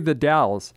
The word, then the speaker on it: The Dalles: Yeah, it's actually technically the Dalles. – The Dalles. How'd I do? – Yeah, there you go.